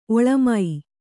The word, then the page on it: ಒಳಮೈ: oḷamai